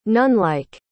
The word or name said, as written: nunlike